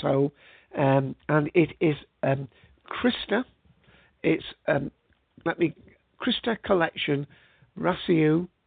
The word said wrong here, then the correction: Rasteau: Rasteau from France,